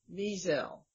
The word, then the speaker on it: Wiesel: I try to emphasize the first syllable, Wiesel. Wiesel.